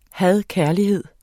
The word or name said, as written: had-kærlighed